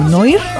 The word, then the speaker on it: Noir: Se escribe N-O-I-R, ¿no? – Sí, bueno, digo, coloquialmente lo pronunciarían como Noir, pero se pronuncia Noir.